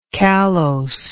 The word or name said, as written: callose